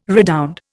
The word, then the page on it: redound: Indian